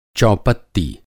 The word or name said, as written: चौपत्ती